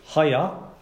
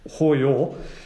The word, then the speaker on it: hayah: It's the same Hebrew word Hayah, or Hoyor, it's spelled H-A-Y-A-H, the way it's translated into English.